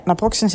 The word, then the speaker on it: naproxen: Eh, en daar zit uh, naproxen in. Zeg ik het goed? Eh, ja, naproxen zit daarin.